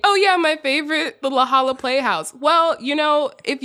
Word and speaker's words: La Jolla: For years, I have been saying La Jolla Playhouse, and it is, it's what? La Jolla? – Yeah. – That should not be pronounced like that.